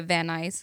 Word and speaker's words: Van Nuys: you can just say Van Nuys.